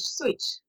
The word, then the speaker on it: Szucs: And in fact, with Zooks, I've seen naturalization records in particular that where they've changed the name from S-Z-U-C-S to S-U-I-C-H, switch. – Huh. – That Hungarian pronunciation.